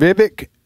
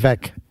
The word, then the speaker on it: Vivek: So, with Vivek Ramaswamy, I think that's the way, isn't that the way you pronounce it? – Vivek. – Yeah. – Vivek.